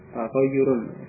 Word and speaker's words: taghayyurun: Jadi ini tulisannya yaknya hanya satu, ya, membacanya taghayyur. Taghayyurun. Kalau ianya dua itu tahyir. Ini berbeda ya. Ini perhatikan tulisan dan ucapannya, bentuknya. Taghayyurun. Ini ianya dua. Tapi kalau di situ ianya satu. Berarti membacanya taghayyur.